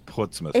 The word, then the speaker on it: Portsmouth: Portsmouth. – Is it Mouth? – Yes, but it's pronounced Portsmouth.